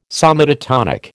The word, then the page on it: somatotonic: American English